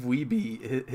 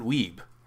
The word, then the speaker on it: Wiebe: One of the underrated things is Steve Wiebe, his name is spelled W-E-I-B-E. And for some reason, the people that are involved with the scoring committee, they pronounce it Weeb. And they do it so often that he gets annoyed with it. Mm-hmm. And he corrects them.